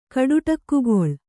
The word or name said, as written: ಕಡುಟಕ್ಕುಗೊಳ್